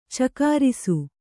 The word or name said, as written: ಚಕಾರಿಸು